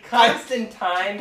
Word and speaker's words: Constantine: That is not. Constantine. – That's how you pronounce it.